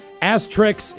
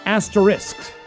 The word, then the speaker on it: asterisk: Asterix is incorrect, but I do like asterix, depending on who does them. The correct pronunciation is asterisk.